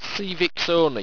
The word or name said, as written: sievixawni